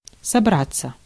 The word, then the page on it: intend: /ɪnˈtɛnd/